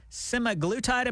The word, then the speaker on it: Semaglutide: Semaglutide, am I saying it correctly? – Yes, you are.